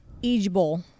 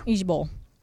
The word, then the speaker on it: IJBOL: In lieu of laugh out loud, it's IJBOL. – IJBOL. – Pronounced Ejbol. – Nope. Too many letters to remember. – Ejbol.